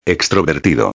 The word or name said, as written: extrovertido